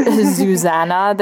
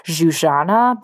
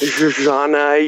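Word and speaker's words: Zsuzsanna: I've been calling her Zuzana this whole time, and you taught me that it's Zuzana Budapest. – Zuzana, yeah. Like, again, that's my Hungarian heritage coming through with the European pronunciation.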